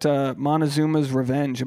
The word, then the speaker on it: Montezuma's revenge: Hey, I'm talking about Montezuma's revenge. Am I pronouncing that right? – Yes, that is exactly correct.